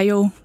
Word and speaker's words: Io: Den der ene måne, der hedder Jo, eller Io, der har lidt forskellige udtaler, den kommer nogle gange til syne tidligere, og nogle gange senere, end den skulle.